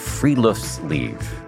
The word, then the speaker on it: friluftsliv: We're going to talk about the Scandinavian concept of friluftsliv. I can't believe I pronounced that correctly.